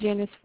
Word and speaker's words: Janice: My name is Janice Ford. – Janet? – Yeah, spell it for me. – J-A-N-I-C-E. – G-M-I-A? – J-A-N-I-C-E.